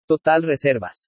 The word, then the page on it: TOTAL RESERVAS: spanish